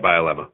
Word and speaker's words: Bielema: You also said, too, the former Arkansas head coach, Brian Bielema. Am I saying that correctly? – Yeah, Bielema.